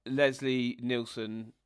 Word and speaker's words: Nielsen: As much as Leslie Nielsen...